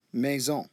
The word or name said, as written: maison